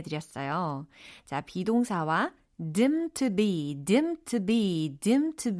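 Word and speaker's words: deemed to be: dimmed to be 이렇게 pp형으로 어, 적혀 있는데 얘를 발음을 할 때는 dim to be 마치 없는 것처럼 이렇게 자연스럽게 연음 처리가 되니까 참고해 주시면 좋겠어요.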